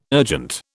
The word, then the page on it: urgent: British English